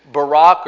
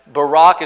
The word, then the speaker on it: Barak: She's the one that Deborah prophesies to Barak or Barak. I'm going to probably say Barak. Barak is probably the right way to say it, but all my life I've said Barak.